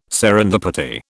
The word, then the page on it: serendipity: British English